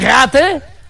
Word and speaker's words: Ratte: Die Ratte hat schon geweint.